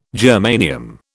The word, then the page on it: germanium: British English